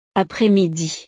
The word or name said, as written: après-midi